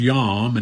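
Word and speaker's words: yom (יום): The Hebrew word for continually here means for the process of time. It's pronounced yom, and that's what it means, the process of time.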